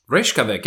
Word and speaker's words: Reykjavik: They are a rap rock band from the city of, if I can pronounce this correctly, Reykjavik? – Reykjavik. Yeah, I think you got it.